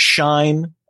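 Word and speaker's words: Shein: And I thought it was going to be, oh, maybe this will be the next Shine, as I now know it's pronounced.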